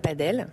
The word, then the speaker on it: padel: Padel. Voilà, on dit plutôt padel dans la prononciation pour essayer de différencier.